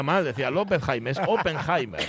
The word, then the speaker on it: Oppenheimer: Hay quien lo llama López Jaime. El apellido ah, es Oppenheimer. Oppenheimer. Oppenheimer.